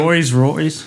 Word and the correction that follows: Rolls Royce: Rolls Royce.